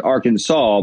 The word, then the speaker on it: Arkansas: It's A-R-K. It's Kansas. It's spelled the same way, but it's pronounced Arkansas.